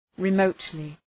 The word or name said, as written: remotely